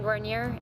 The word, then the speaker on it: Guarnier: I know that's how she pronounces her name because I looked it up. – My name is Megan Guarnier.